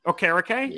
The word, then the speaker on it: Okereke: So if they're going to want to come in, I know they signed uh, contentious name pronouncing um, Bobby Okereke. Okereke, yeah, there it is.